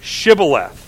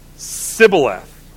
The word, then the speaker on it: Shibboleth: And then if the person from Ephraim, verse 6, if they said no, then they would say, all right, then say the word Shibboleth. And he would say Sibboleth, for he could not pronounce it right.